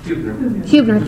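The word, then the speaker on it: Hübner: but Helmuth Hubner? – Hubner, thank you.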